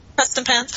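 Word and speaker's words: Prestonpans: And to me, one of the very best episodes has been Preston Pans. Am I saying that right? It's weird when you don't say these things. I type them, but I don't say them. – You're saying it right.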